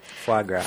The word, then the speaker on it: foie gras: Foie gras.